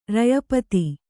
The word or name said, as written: ರಯಪತಿ